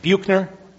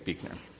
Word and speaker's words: Buechner: Buchner? Does that sound right? Buchner? Frederick Buchner? Buechner? Buechner. I think that's the right way to say it, Buechner.